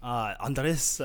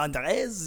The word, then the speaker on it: Andres: I am Liam Andres. – It's Andres. – Andres. – It's very, people see it and they try to, they're like, do I roll my R's when I say it?